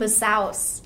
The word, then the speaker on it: Bisous: I just felt like, you know, people were like, oh, Simone Biscuit. Like, Simone Bisous.